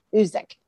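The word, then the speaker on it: Guzik: It's G-U-Z-I-K, Uzik. And I think the G is silent, but it's Enduring Word.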